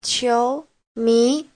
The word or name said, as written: qiu2mi2